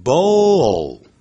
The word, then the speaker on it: bowl: 像我们吃饭的碗，bowl，念成 bowl，不要念成 bow，因为你看它的音标在尾巴有个 l 这个符号。我曾说念成欧洲的欧，但舌尖抵在的上排牙齿的后头，就是上齿龈的后头。 bowl。这样才对的，不是 bowl，念 bowl 的时候舌尖是没有翘起来的， bowl 的时候舌尖翘起来， bowl。